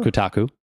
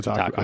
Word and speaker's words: Kotaku: Yep, from Kotaku. – I always thought it was Kotaku. Is that how you say it? – I'm pretty sure it's Kotaku.